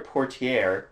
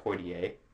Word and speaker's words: Poitier: So the first thing that he said is Taylor Portier, which is how you pronounce his name apparently, and not Poitier.